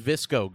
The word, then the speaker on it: VSCO: Kate found out she mispronounced VS. She was calling them VSCO girls on the show before. Hey, thank you to Pisscom for the 3-month sub. Anyway, so now it's actually pronounced visco girls. So it's visco girls.